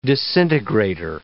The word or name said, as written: disintegrator